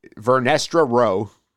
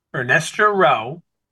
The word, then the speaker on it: Vernestra Rowe: Vernestra Rowe. – Vernestra Rowe.